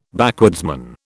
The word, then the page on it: backwoodsman: British English